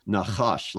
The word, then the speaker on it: Nahash: So in English, it looks like we would pronounce it Nahash, which I guess we can pronounce it that way. But in the Hebrew, it's Nahash, like you would write it in a C-H. A-S-H.